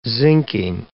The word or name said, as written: zincking